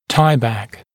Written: tieback

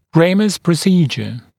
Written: ramus procedure

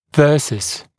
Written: versus

